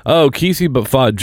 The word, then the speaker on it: KSI: Um, no, the other guy, Kesey. – I think it's it's it's it's uh, KSI. That's how you say his name.